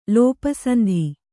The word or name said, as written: ಲೋಪಸಂಧಿ